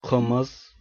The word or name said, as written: қымыз